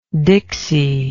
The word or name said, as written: dixy